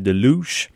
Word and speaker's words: The Louche: Et juste avant, c'était The Louche, qui s'écrit, en fait c'est comme en français une louche, mais c'est The Louche, comme de douche, et c'est la chanson Salt.